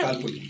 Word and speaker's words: calpul: Pero con el acento en la A o en la I. – En la A. – Bueno. Calpul. – Calpul está bien.